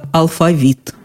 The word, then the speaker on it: алфавит: Но в любом случае ударение в слове «алфавит» неизменно только «алфавит».